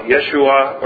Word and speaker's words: Yeshua: That's not how they pronounced it, of course. That's our way of saying it. In the Greek New Testament, it's something like Yesu. In the Hebrew or Aramaic they would have spoken, it would have sounded something more like Yeshua or something like that.